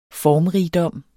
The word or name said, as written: formrigdom